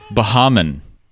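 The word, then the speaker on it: Baha Men: apparently, it is not pronounced how most people would think, which is the Baja Men. They pronounce it themselves at the start of one of the songs, and it goes like this.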